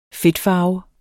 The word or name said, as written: fedtfarve